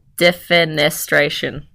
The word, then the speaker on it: defenestration: Defenestration.